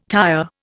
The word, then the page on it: tyre: Indian